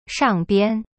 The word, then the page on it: 上边: shang bian